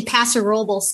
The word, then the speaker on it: Paso Robles: I grew up in Paso Robles, California.